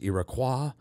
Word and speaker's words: Iroquois: The word itself is French, properly pronounced Iroquois, but there's a lot of debate about its origins.